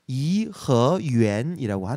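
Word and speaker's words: 颐和园: 이화원은 중국어 발음을 이허유엔이라고 하는데 이허유엔.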